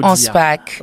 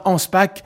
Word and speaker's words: Anspach: Est-ce que je le dis comme il faut dire? – Boulevard Anspach. – Voilà, boulevard Anspach, dont au numéro 30 à Bruxelles, dont l'événement commencera à 18h.